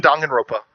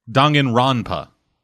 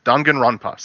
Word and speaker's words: Danganronpa: Okay, now you're pissing me off with this pronunciation. – You're saying it wrong. – Yeah. Danganronpa. – Oh, Danganronpa. I never have noticed that in. Danganronpa. Sorry. I will make a point to pronounce it properly.